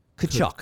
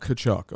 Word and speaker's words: Tkachuk: The T is silent. Tkachuk. – Tkachuk, okay.